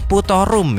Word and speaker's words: poetorum: Terus yang terakhir, yang keempat, ada Sweetipilus poetorum. Nah, atau dibacanya putorum ya, jadi tulisannya poetorum.